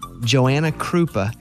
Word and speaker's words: Joanna Krupa: Joanna Krupa. Am I saying her name right? Joanna Krupa. – I think so.